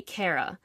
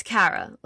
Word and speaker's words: Kara: A lot of people say Kara. It's Kara. Like, a lot of guys, like, half don't hear the difference, Kara versus Kara.